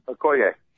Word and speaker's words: Okoye: Real quick, and I love, I was not aware that Okoye, is that how you pronounce his last name? – Okoye. – Okay. – Like Christian Okoye is the same name. Okoye is like Smith over in Nigeria.